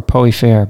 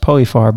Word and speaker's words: Poeyfarre: The other street that I like, just because it's fun to say, is Poey Far or Poey Fair, but it's mostly pronounced Poey Far by New Orleanians, P-O-E-Y-F-A-R-R-E.